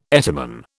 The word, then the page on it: etymon: British English